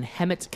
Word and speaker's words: Hemet: He said it right Hemet.